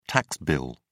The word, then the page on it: tax bill: British English